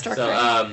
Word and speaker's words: Darkrai: Yeah, it's Darkrai.